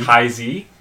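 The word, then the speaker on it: Taizé: Ty-Z. – So that's, he has like, no relation. – It's like T-A-I-Z and then an apostrophe on the E.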